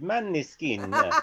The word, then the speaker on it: Måneskin: I know, because they're going to try and pronounce that in an Italian accent, aren't they? Not the Danish accent. – Yeah, even the Italians didn't get the name right. Måneskin. I'm expecting ours to call them Måneskin.